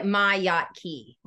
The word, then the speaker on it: Miotke: Yeah, it's like Miotke.